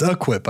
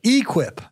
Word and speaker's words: equip: It's it's it's equip, I think, but he's like equip. But um, he, he puts the wrong emphasis on the wrong syllable.